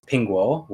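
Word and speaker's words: pingguo (苹果): The Mandarin Chinese word for apples pronounced as pingguo, which I can attest is correct, which sounds similar to peace.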